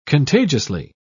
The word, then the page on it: contagiously: British English